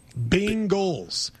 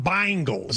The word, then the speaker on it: Bengals: I say it like people in Cincinnati say it. Bengals, not Bengals. – There's no I. Then it should be B-I-N-G-A-L-S.